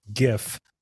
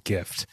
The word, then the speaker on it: GIF: So the acronym is most commonly pronounced GIF with a hard G, as in gift, or GIF with a soft G, as in gem, differing in the phoneme represented by the letter G.